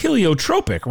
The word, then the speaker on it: heliotropic: Did I pronounce it right? Which is it's heliotropic, right? – Heliotropic effect. That's exactly right.